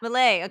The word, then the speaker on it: Milei: Milei. – Ah, Milei. Okay, I was close. – It rhymes with filet, like filet.